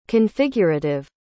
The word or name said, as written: configurative